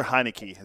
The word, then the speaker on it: Heinicke: And Matthew Tyler Heinicke is how you say it.